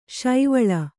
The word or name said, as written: ಸೇವಾಲ